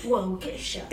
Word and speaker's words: Waukesha: Waukesha. Te juro que se dice así.